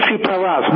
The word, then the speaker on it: Tsipras: Alexis, how do you pronounce the name? Tsipras? – Alexis Tsipras.